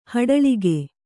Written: ಹಿಟ್ಟಳಿಕೆ

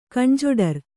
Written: ಕಣ್ಜೊಡರ್

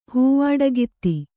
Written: ಹೂವಡಿಗಿತ್ತಿ

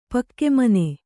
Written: ಪಕ್ಕೆಮನೆ

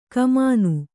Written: ಕಾಮನೆ

